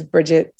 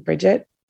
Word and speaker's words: Bridgette: which is Bridgette Simmonds, my first and last name, Bridgette Simmonds with a D, a silent D at the end.